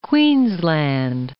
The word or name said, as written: Queensland